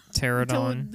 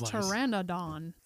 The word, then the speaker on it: Pteranodon: We've said this ride incorrectly our whole lives. – Pterodon. – This time we saw it. It's Pteranodon.